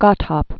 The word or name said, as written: Godthab